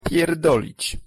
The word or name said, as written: pierdolic